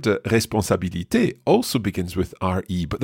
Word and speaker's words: responsabilité: Responsabilité. – One more time. – Responsabilité.